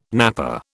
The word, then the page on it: nappa: British English